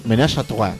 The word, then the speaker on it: ménage à trois: El sexo grupal que involucra solo tres participantes se llama Ménage et Trois. – Ménage à trois. – Término francés que se pronuncia.